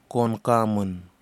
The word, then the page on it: konkamun: Kwamera